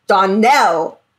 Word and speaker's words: Donnell: Anne's like, oh, you're the Donald twins, right? And Clarice is like, the stress is on the last syllable. Donnell.